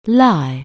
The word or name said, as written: lie